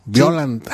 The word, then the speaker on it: Biollante: Y luego sí se pronunciaba... Ay, Biollante. No, Biollante. Sí. Ay, ¿cómo decían? – Es Biollante, pues. – Biollante, porque yo ahí me echaban carrilla, Biollante, pero no, en japonés sí, ya después que la vimos grandecillos sí decían.